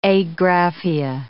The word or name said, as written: agraphia